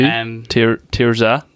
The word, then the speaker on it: Tirzah: So the track I have is by an artist called Tirzah. – Who? Tirzah. – It's spelled T-I-R-Z-A-H.